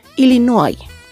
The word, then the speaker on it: Illinois: El nombre del estado de Illinois se escribe exactamente igual que en español, pero se pronuncia de manera muy distinta debido a su origen francés. Escritura Illinois, pronunciación Illinois.